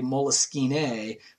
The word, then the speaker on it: Moleskine: So I can't speak French, but it's Moleskine is like the French pronunciation. Sometimes you even hear people say Moleskine with a little accent on the E.